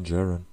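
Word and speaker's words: Djarin: Genau, und äh, Mando oder The Mandalorian oder den Jarin. Wie, wie, wie sprichst du den aus? – Den Jaren. – Den Jaren, ah, ja. Ich habe zu deutsch ausgesprochen.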